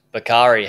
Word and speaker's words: Bakari: Bakari, hello, welcome to the podcast.